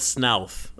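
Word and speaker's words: Snoths: Am I pronouncing that right? Is it Snouts? Is there an agreed-upon pronunciation? Does anyone care? – Dave Goles says Snouth. I think we should say, if Dave Goles says it's Snouth, it's Snouth.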